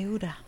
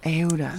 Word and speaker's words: Aouda: Yeah, Aouda. – Aouda.